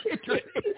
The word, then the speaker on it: chitterlings: Chitterlands. – Chitterlings. – Oh, God. Moody noodles. – Chitterlings. It's pronounced chitterlings. It's chitterlings, man.